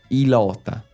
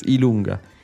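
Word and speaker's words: i lunga: We actually, the funny thing is that on the speak and spell, the J was there and he would pronounce it Ilota. – Ilota. – That's wrong. It doesn't exist in Italian. It's Ilunga.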